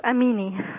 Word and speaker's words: Amini: Am I pronouncing your last name right? Amini? – Yes, Amini. – Amini. A-M-I-N-I.